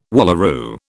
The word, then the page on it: wallaroo: British English